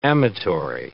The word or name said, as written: amatory